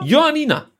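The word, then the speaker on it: Ioannina: Joannina, ganz einfach. Ja, ja. Johannina. – So spricht man es aus.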